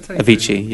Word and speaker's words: Avicii: Did Avicii. Is that how you say it? – Avicii, yeah. Avicii, I thought he was correct.